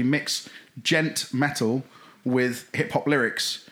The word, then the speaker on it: djent: which is spelt D-J-E-N-T, gent, because that's the descriptor of the noise it makes on the guitar string when the pick hits the string.